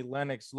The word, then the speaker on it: Lennox: Don't call me Lennox Lewis. And we were just talking before. That's the right way to pronounce it, because now we can just squash this now once and for all, because Twitter just says it over and over.